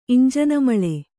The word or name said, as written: ಇಂಜಾನುಮಳೆ